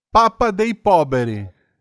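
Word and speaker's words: Papa dei Poveri: Tratado como rei na Itália, é chamado de Papa de Poberi. Como é que é a pronúncia disso? – Papa de Poberi. Meu italiano não. Tem que falar cantando. Papa dei Poberi.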